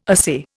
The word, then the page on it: assay: Indian